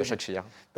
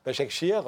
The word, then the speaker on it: Başakşehir: Başakşehir. – Başakşehir.